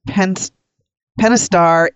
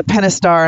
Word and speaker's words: Pentastar: Well, Tony and Brian, the 10 millionth Pentastar engine was produced on February 13th at the FCA U.S.